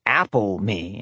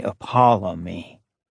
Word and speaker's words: Apollyme: It sounded more like Apple-me instead of their way, Apollyme.